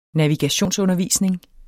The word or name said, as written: navigationsundervisning